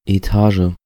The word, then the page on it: Etage: IPA: /eˈtaːʒə/; German